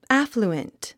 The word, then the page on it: affluent: ǽfluənt; アッフルエント